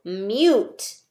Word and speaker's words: mute: So mute has our long U sound. Remember, long U says its name, U. N-U, N-U, N-U. So when we sound it out, you should be able to hear me say U. Ready? Mute, mute. Yep, we can hear it. Mute has the long U vowel sound.